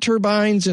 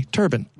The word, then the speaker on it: turbine: Now, do you pronounce it turbine or turban? – Well, either way, depending, I guess, on who I'm talking to. I think it's turban, but I do too.